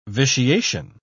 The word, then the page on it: vitiation: British English